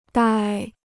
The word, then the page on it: 呆: dāi